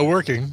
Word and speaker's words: Werking: David Working... I'm just saying that's a hair. It's not a great name. – Sure, Working. – Yeah, Working. It's spelled like jerking without the J's. That's what's funny. W-E-R-K-I-N-G, who was living with his parents following a divorce, sued them over the items which he claims were worth over $25,000 U.S.